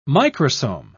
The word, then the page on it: microsome: British English